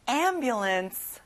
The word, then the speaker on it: ambulance: 구급차, ambulance. 앞에 에 소리를 내보세요. ambulance.